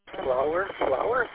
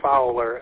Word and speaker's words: Fowler: Flower? Maybe it's Flower, Michigan. I don't know. I'm probably saying it wrong. So go ahead. – Yeah, I had a pretty good day down, so it's Fowler, F-O-W-L-E-R.